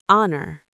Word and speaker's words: honor: Yes, in huddle you hear it, but not in honor.